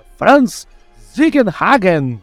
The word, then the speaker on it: Franz Ziegenhagen: É o Franz Ziegenhagen. Agora sim, falei certo, hein? Esse eu treinei.